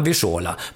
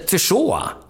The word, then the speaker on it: Wyszoła: Och det sa vi när det kom på telegram, Wyszola. Men han hette Wyszoła, inte Wyszola. Wyszola var stavningen, Wyszoła var uttalet.